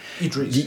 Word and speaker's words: Idris: Iris Elba, ik kan even zijn naam niet goed uitspreken. – Idris, die vond ik zelf niet de beste keuze.